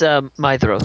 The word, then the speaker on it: Maedhros: Madros, oldest son of Fëanor. Is that how y'all pronounce that? Madros? – Yeah. – Madros? – It's Maedhros. – That's right. D-H is a Maedhros. T-H. Maedhros.